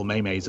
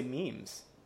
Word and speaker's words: memes: Well, he's paying like people like just doing like awful maymays of him, and it turns out that he's paying for them. – I don't know if I know anybody else who pronounces it maymays. I would say memes.